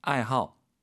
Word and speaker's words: 爱好: 培养出 이份 아이하오来, 아이하오. 취미죠. 뒤에 그 하오 그 발음 호자의 발음이 하오가 아니고 하오 사성으로 한다라는 거 좋아하다.